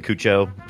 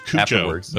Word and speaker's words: Cuoco: I don't think it's Cuoco, but keep going. You're fine. – Oh, how do you say it? – I say it like she says Cuoco? It, which is Cuoco.